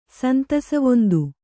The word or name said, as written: ಸಂತಸವೊಂದು